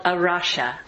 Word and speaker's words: A Rocha: Maybe you've seen this phrase but weren't quite sure how to pronounce it. It's called a rocha, which means the rock.